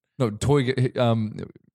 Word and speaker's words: Toyger: Sort of pronounce it like you were a villain in Austin Powers. – Please, have demonstrate. – Toyger.